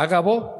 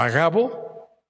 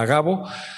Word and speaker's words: Agabo: Más adelante baja un profeta que se llamaba Ágabo, o Agabo, no tiene acento en la A, Agabo, y agarra el cinturón de Pablo y dice, así van a atar al dueño de este cinturón.